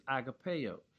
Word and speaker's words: agape: The word used here for love is agapeo, which we commonly pronounce as agape.